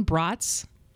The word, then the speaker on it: brats: Some more obvious than others, but number one, brats rhymes with cots, not cats.